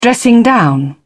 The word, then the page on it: dressing-down: British English